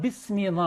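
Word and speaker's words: 비스미 (بسم): 하저스투하 비스미 나아세론 비스미 여기도 있음인데 비가 붙었으니까 이 알리프가 발음되지 않죠.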